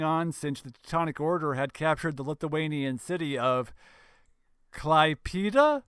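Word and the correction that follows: Klaipėda: It's not an E, it's an... Okay, imagine that in place of that E, there's an A, and it's a long A. – Klaipeda.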